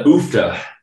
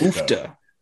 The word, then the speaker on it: UFFDA: The OOFTA podcast. – OOFTA.